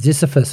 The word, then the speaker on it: Ziziphus: And Ziziphus, have I pronounced that correctly? – Yes, you have.